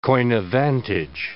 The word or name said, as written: coign of vantage